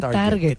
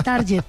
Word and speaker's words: target: Las juntas no digan target. – Se dice target. Sí, a ver, aquí está el target. No, es target. En inglés se pronuncia target.